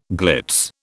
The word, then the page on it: glitz: British English